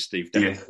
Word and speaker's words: Death: So I think it is Steve Death. Death. Everyone knows, you know, Graham Nicholas called him Steve Death.